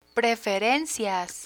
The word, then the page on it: preferencias: Spanish